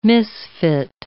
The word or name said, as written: misfit